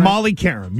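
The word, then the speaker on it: Molly Qerim: Am I saying her name right? – Molly Curum, yes. – Curum, okay. It's a Q there. I don't know why it sounds like a K, but that's fine.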